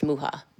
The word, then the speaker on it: Myxa: For those who aren't familiar, it's spelled M-Y-X-A, pronounced Muha.